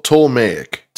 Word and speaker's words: Ptolemaic: Say it one more time. – Ptolemaic. – Ptolemaic period. – And the palatial, P is silent.